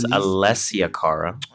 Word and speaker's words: Alessia Cara: Alyssa Cara, Growing Pains. – Yo, you're not even ever saying her name right. It's Alessia Cara.